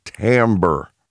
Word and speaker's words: timbre: But she says, what you are liking here on this song, Rick, is you are liking what is called the timbre. And that's spelled T-I-M-B-R-E. Now, how you get the pronunciation timbre out of that spelling, I don't know.